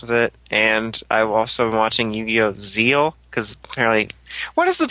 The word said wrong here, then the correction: Zexal: It's Zexal. It's Yu-Gi-Oh! Zexal.